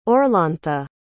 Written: orlantha

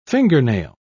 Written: fingernail

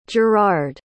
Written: gerrard

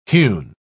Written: hewn

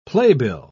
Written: playbill

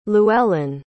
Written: llewelyn

